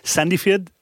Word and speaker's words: Sandefjord: In someone did email us with the correct pronunciation because we completely mangled this, but Sandefjord in Norway.